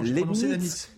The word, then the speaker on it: Leibniz: Lanitz. – Bon, mais parce que vous l'avez mal prononcé, c'est Leibniz qui est contemporain de Voltaire, nous sommes d'accord. – Ah, oui, c'est Leibniz.